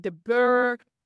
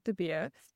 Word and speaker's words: DeBeer: I've had DeVere, I've had DeBeer, I've pretty much had everything, and I try and explain it as beer. You do the whole, you're chugging down a beer. I'm Odette DeBeer. – And that's how it's spelled. It's B-E-R.